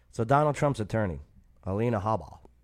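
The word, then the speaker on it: Habba: Alina Haba. I believe that's how it's pronounced. – Haba.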